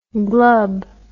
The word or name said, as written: glub